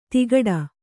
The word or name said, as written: ತಿಕಟ